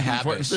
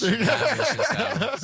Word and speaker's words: Habish: Now we can get to Habish. Habish is my last name. In Arabic it's just HBSH, and then we have the short vowels, which are the A's, so Habish, Austin Habish.